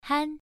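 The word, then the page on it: han: tone 1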